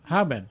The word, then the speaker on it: Harbin: Where are they from in China? – Harbin? – Harbin. – He's saying it right. – H-A-R-B-I-N.